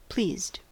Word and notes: pleased: American English

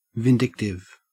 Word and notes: vindictive: Australian English

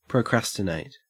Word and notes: procrastinate: Australian English

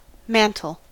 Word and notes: mantle: American English

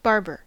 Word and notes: barber: American English

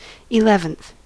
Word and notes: eleventh: American English

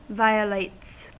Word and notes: violates: American English

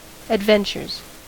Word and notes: adventures: American English

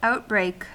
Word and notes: outbreak: American English

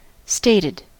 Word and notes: stated: American English

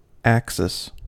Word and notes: axis: American English

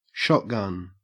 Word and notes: shotgun: Australian English

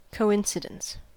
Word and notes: coincidence: American English

